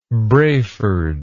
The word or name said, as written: Braford